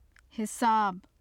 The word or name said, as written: hisab